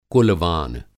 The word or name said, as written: कुल-वान